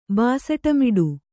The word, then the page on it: ಬಾಸಟಮಿಡು: Kannada